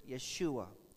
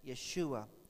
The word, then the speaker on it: Yeshua: They don't have a J, so they pronounce it Yeshua.